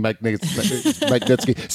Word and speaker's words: Magnitsky: Majinsky Act meeting. Magnitsky. See, now you got me saying it wrong.